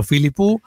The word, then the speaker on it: Philippou: Philippou es un apellido griego. No, no soy experto en apellidos internacionales. Hay algo que se llama Gemini y le preguntas cómo se pronuncia tal cosa, Philippou. Y puede variar de acuerdo a la región de donde sea o el dialecto del que sea, pero Philippou es la forma, pues, más, digamos, tradicional de decirlo.